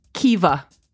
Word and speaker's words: Caoimhe: So this name is spelled C-A-O-I-M-H-E. And it's pronounced Kiva. There's no V in that word. There's no like E. Well, there's an E at the end, but it looks like K-O-M-H-E. And it's pronounced Kiva. So another example is N-A-I-M-H.